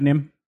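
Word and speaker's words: NIMH: The Secret of Nim.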